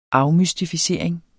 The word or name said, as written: afmystificering